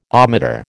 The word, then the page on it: ohmmeter: American English